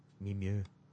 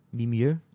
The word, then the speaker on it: Mimieux: Mimieux. – Mimieux. How do you say it? – Mimieux. Yvette Mimieux.